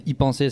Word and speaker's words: e-penser: Donc pour ceux qui ne voient pas le livre, qui ne connaissent pas la chaîne, y penser, ça s'écrit c'est à l'anglaise en fait, c'est e-penser, donc y penser.